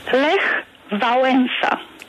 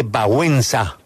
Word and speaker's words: Wałęsa: Siempre decimos Lech Walesa, Lech Walesa o Lech Wałęsa. ¿Cuál es la correcta pronunciación? – Lech Wałęsa. – Perfecto. Pregúntele al presidente Wałęsa.